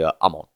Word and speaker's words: Amon: If you're going strictly off the Japanese, it would be Akira, yes, and then it would be Amon.